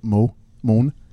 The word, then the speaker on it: Mons: Which is in Mons, Belgium. As a Belgian pronounce it, Moan.